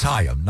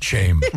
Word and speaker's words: Chaim: It's Chaim, not shame.